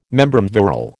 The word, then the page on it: membrum virile: American English